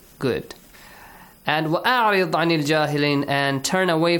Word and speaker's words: وَأَعْرِضْ: Only the letter Ra has to be pronounced correctly. وَأْمُرْ heavily. وَأَعْرِضْ رِ lightly.